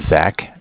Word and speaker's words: Zach: So my name is Zach, spelled Z-A-C-H. – Okay. – I'm calling from Bank of Cardiff. Cardiff is spelled C-A-R-D-I-F-F, like Cardiff, Wales.